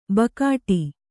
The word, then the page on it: ಬಕಾಟಿ: bakāṭi